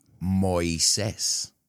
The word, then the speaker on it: Moisés: It's interesting, because in Spanish, do you know how they pronounce Moses? – No, actually, I don't. – Moisés. – Moisés? – Uh-huh.